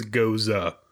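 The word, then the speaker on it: Gose: There's a pronunciation guide in the back for how to pronounce stuff. And yeah, it is goza.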